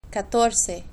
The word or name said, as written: catorce